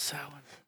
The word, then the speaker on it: Samhain: If somebody corrected him, you know, took him aside and was like, it's actually pronounced Samhain.